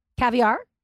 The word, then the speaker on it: caviar: Yeah, it's like the emphasis is on the ca. – Caviar? – Yeah, why are you putting the emphasis on the R?